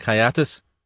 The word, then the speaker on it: hiatus: There is consequently a phatic hiatus." He pronounced this so as to rhyme with "get at us."